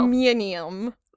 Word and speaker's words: aluminium: Lots of aluminum, to quote an Alex pronunciation of that word. – I was confused for a second. I was like, no, that's the normal way to pronounce that word. – Listen, friend, you are outnumbered by the Commonwealth.